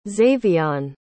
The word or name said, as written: xavion